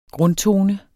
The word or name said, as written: grundtone